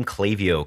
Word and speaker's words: Klaviyo: It's one of those ones that like I heard about it, and then within a day I'd heard, you know, it's pronounced Klaviyo, right? It's, you know, like it has that whole like meta thing where people are talking about it. Where did the name come from? – Yes, we have those stickers and shirts that say the phonetic pronunciation.